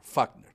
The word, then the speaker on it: Wagner: Y es utilizar, por ejemplo, la compañía Fagner, se escribe Wagner, Fagner.